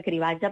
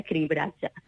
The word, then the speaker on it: cribratge: Exacte, sí que és veritat que els mitjans han tendit a simplificar, i aquesta segona R, es permeten dins dels mitjans de la corporació catalana es permet aquesta pronúncia cribatge, però en principi, clar, aquesta segona R hi és, cribratge.